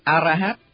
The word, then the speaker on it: A-ra-hát: Và do đó vẫn giữ nguyên lối phát âm theo tiếng Phạn là A-ra-hát.